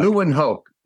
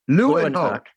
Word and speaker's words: Leeuwenhoek: There was a scientist named, and I'm going to butcher this, but Anthony Van Leeuwenhoek. – Leeuwenhoek. – Leeuwenhoek. – Yes, yeah. – That's right.